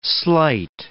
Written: slight

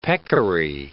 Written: peccary

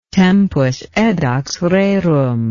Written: tempus edax rerum

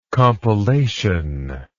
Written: compilatory

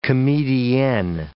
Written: comedienne

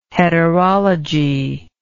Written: heterology

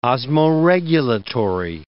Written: osmoregulatory

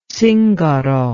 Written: zingaro

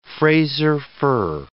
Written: Fraser fir

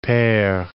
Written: pere

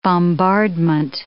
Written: bombardment

